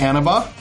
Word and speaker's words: Annabah: Have a great incoming student, Annabah.